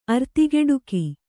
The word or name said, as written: ಅರ್ತಿಗೆಡುಕಿ